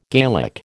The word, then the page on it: Gaelic: American English